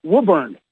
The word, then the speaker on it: Woburn: Woburn.